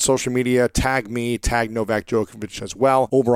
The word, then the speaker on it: Novak Djokovic: We have the legendary Novak Djokovic.